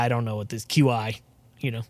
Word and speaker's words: Qi: QI? – Is that what we're talking about? Yeah, it's funny because I just found out that that's pronounced Qi right before this podcast because we were looking at articles and I was like, huh, go figure.